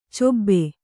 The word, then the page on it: ಚಬ್ಬೆ: cabbe